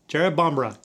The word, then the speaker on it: Jerrabomberra: So, Jerrabomberra. Jerrabomberra. That's how you say it.